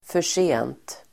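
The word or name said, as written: försent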